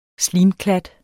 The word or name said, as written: slimklat